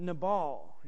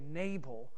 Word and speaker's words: Nabal: And we're going to be introduced to a new man named Nabal. You may say Nabal, most likely pronounced Nabal.